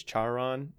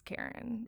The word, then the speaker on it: Charon: I think it's Charon. If you wanted it, it's not. It's it's Karen.